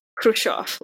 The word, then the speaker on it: Khrushchev: This is, for reference, Khrushchev. Like, this is it. It's very simple. There is no need for a K or an extra CH.